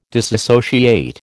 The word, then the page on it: disassociate: American English